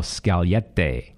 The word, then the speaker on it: Scaglietti: And you're Italian, so you can correct my pronunciation if I need it here. A 2005 Ferrari 612 Scaglietti. Is that correct? – Yeah, pretty good.